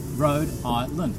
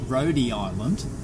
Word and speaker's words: Rhode Island: Rhode Island. Three. It's not Rhodey Island.